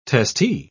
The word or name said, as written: testee